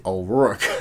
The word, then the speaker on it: O'Rourke: O'Rourke.